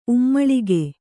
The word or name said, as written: ಉಮ್ಮುಳಿಗೆ